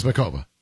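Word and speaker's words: Muchová: Muchova. – So I watched the entire thing. They never pronounce the C. So it's Makova. That's how they say it.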